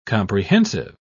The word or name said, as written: comprehensive